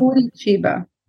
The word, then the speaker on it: Curitiba: Curitiba.